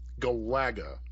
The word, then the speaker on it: Galaga: Galaga is the official. – I just found out is the official pronunciation. – All right. So it's the emphasis is on the second syllable. – Second syllable. Yep.